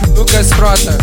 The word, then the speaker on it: Lucas Frota: Lucas Frota.